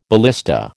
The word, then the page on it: ballista: American English